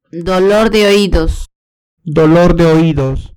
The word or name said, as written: dolor de oídos